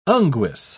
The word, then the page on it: unguis: British English